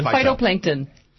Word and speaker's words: phytoplankton: Am I saying that right? – Phytoplankton. – Phytoplankton. Yes.